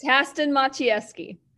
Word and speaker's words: Haston Maciejewski: No, absolutely not. – Oh crap! All right, that's all right. It's Haston Maciejewski.